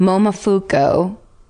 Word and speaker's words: Momofuku: Momofuku.